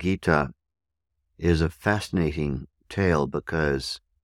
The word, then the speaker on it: Gita: Bhagavad is spelled B-H-A-G-A-V-A-D. Bhagavad, Bhagavad, Bhagavad. Second word, Gita, Gita.